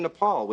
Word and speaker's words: Nepal: I just got a photo gig in Nepal, which is not pronounced nipple. Learned that one the hard way.